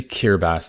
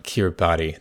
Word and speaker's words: Kiribati: And I want to acknowledge that I really mispronounced it more than once. It should be Kiribati and not Kiribati the way that it's written.